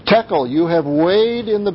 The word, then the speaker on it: tekel: Mene, mene, tekel, upharsin. Mene means numbered, and it's mentioned twice, which shows that he has Belshazzar's kingdom has been numbered and it's time's up. Tekel, which rhymes with shekel, tekel is to weigh coins.